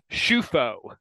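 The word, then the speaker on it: Ciuffo: All due respect to the Cioffo family. – Or what, however it's pronounced, but yeah. – Baseball reference tells me that it's Schufo, Nick Schufo, which I guess makes more sense.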